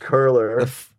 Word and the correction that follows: cruller: It's spelled cruller. C-R-U-L-L-E-R. – Oh, a cruller.